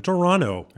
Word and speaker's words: Toronto: We were in Toronto. – That's right. – You don't pronounce the second T, according to Argo.